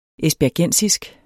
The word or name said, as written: esbjergensisk